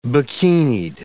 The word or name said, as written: bikinied